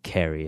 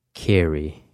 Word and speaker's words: Carey: I think I've been saying it wrong. Not Carey. I think it's Carey. Yes. I have definitely been saying that wrong.